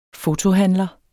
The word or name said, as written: fotohandler